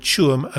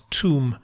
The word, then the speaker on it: Tuam: One note about it, he sometimes mispronounces the word Tuam as tomb.